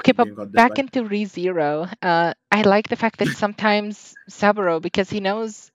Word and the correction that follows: Subaru: Subaru. – Subaru. – It's like the car. It's actually like the car, Subaru.